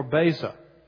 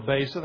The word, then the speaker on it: Beza: His name is Theodore Beza. Theodore Beza, that's spelled B-E-Z-A.